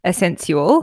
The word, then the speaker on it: Essensual: It's spelled Essensual, lube, not essential, Essensual.